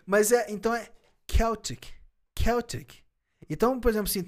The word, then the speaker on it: Celtic: O irlandês pronuncia Celtic. Caraca. Porque o C em gaélico é K.